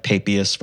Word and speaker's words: Papias: like Papias, for example. I use the anglicized pronunciation for his name,